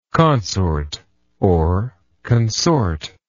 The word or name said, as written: consortion